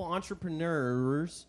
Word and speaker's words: entrepreneurs: And I, you know, I just, I really don't like the way that you guys put down those hardworking, successful entrepreneurs, which is how you say that word. You really have to, the U-E-R, you really have to... – Elongate that.